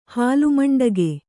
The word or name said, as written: ಹಾಲುಮಂಡಗೆ